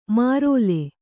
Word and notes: ಮಾರೋಲೆ: mārōle

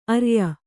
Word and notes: ಅರ್ಯ: arya

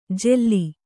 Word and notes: ಜೆಲ್ಲಿ: kannada

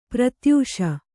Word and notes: ಪ್ರತ್ಯಾಶೆ: pratyāśe

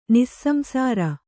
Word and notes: ನಿಸ್ಸಂಸಾರ: nissamsāra

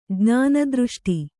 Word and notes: ಜ್ಞಾನದೃಷ್ಟಿ: jñāna dřṣṭi

